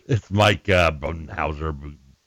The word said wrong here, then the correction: Budenholzer: Buttenbooger, or whatever. I can never pronounce it right. – No, that's why we talk NBA on the show. – Budenholzer. Yeah, Budenholzer. It's something like that. I was close.